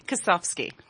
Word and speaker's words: Kosowski: Kosowski. – Kosowski. – Yes. – Where's the F? – The W is pronounced like an F.